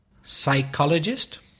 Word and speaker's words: psychologist: I-S-L-A-N-D. Can you think of any more words with silent letters? – Psychologist. – Ooh, that's a 5-point word, that one, psychologist, yeah. – Silent P at the beginning.